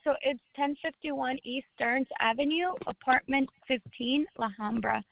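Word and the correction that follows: La Habra: LAHABRA. – Yes, ma'am, I know where La Hombra, how to spell it. Give me one second. – Oh, so it's Habra, H-A, not Hombra, H-A-B-R-A.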